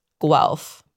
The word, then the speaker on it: Guelph: So it's G-U-E-L-P-H, Guelph.